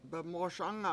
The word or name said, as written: bemOOSanga